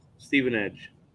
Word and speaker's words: Stevenage: Stevenage.